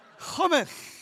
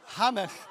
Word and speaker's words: hummus: hummus. Hummus, hummus. I'm married to a Middle Easterner, that's how they say it.